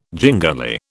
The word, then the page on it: gingili: British English